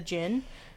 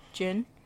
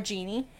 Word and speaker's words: jinn: A form of shadow people in Islamic folklore is the jinn, also spelled as D-J-I-N-N. I think it's still pronounced jinn. – Yeah, it's still jinn. – Yeah, or genie.